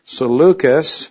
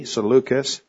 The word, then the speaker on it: Seleucus: Seleucus, that's sort of a hard C, Seleucus, over Syria and Mesopotamia.